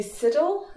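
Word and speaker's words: Siddal: And this is a poem called Lizzie Siddle, if that's how you pronounce it. – Yes.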